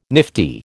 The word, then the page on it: nifty: American English